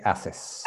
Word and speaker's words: haces: Yes, and the H is silent, remember. ¿Qué haces? – Oh, sorry, I'm not good today.